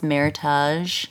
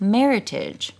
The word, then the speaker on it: Meritage: We also learned that it's not pronounced Meritage, although that sounds very French and fancy. – You're just trying to be fancy. – It's like a Target situation. Meritage is actually meant to be pronounced Meritage, which is like heritage. – Like heritage and merit, and you marry them together, and you get Meritage.